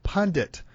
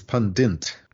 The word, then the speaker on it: pundit: Well, often they put an extra N in, so it comes out as pundint. – Yeah. – Or pundant, like fondant or something.